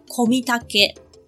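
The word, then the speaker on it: 小御岳: 玉呢，就是御膳房的玉；岳就是岳飞的岳，读作 Komitake。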